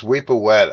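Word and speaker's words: Wepwawet: Yeah, it's Weepawet as opposed to the what they sing during the Lion Sleeps.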